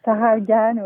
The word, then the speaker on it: Sarah Algan: Alors j'ai noté aussi dans votre programmation un coup de cœur, c'est Sarah Algan, je ne sais pas si ça se prononce comme ça. – Sarah Algan, oui.